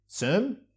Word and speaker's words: Cem: Cem? – It's Cem.